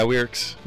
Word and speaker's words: Wierks: Yeah, Wierks.